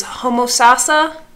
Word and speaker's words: Homosassa: I believe it is Homosassa, which sounds right. – Like a character in The Lion King. But it is, yes, Mufasa, Mufasa, Mufasa.